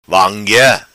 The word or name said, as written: vanguea